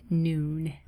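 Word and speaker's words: Nuun: It's N-U-U-N, pronounced Noon.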